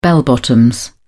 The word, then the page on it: bell bottoms: British English